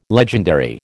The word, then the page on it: legendary: American English